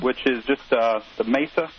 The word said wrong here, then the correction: masa: I know I'm not pronouncing that properly. – I think it's masa. – Masa. – Masa. That's the Spanish pronunciation, dude.